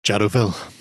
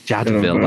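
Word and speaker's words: Jadotville: Jadoville. – Jadoville. That's it. Jado, not Jadot.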